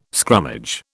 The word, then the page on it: scrummage: British English